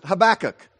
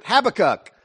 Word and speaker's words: Habakkuk: And by the way, to all my British friends, my European guests today, yes, in America, we pronounce it Habakkuk.